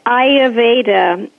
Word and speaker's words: Ayurveda: and I can barely pronounce the word. It's Ayurveda. – Yeah, that's it. – Hi. – Well, yeah, you did it. It's Ayurveda.